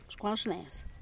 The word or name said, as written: xcuanxnez